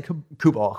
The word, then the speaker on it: Kubal: Let's say Kubal.